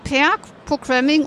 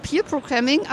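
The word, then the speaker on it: Pair Programming: Also ich durfte jetzt lernen, es heißt Peer Programming und nicht Peer Programming, aber ich habe es immer falsch gesagt, weil ich Peer Learning kenne und das wird ja mit EE geschrieben, aber das Peer Programming kommt von Paaren und wird mit AI geschrieben.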